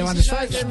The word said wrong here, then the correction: Schweinsteiger: Van, se dice Schweinsteiger. – Schweinsteiger. – Pronunció en alemana.